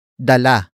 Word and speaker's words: dala: Dalhan is spelled as D-A-L-H-A-N. It is a command to bring something to someone. Dalhan comes from the root word dala. Dala. Dala. Which is spelled as D-A-L-A.